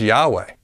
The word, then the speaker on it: Yahweh: Yahweh does not even sound close to Yehovah. In fact, it's not even the same number of syllables. Both the Hebrew Tetragrammaton as vowels or the Greek Tetragrammaton as vowels are both just two syllables.